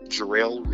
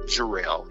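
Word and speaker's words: Jarell: You can find me on Facebook at Trust Jarrell Reloaded. You all can also find me on Periscope at Trust underscore Jarrell.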